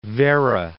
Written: Werra

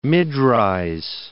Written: mid-rise

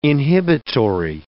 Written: inhibitory

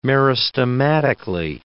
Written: meristematically

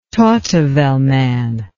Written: Tautavel man

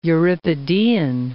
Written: Euripidean

